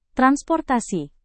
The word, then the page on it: transportasi: Indonesian